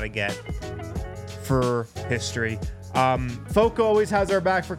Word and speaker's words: FOCO: Guys, check out Foco, pronounced like Fort Collins, but it's F O C O.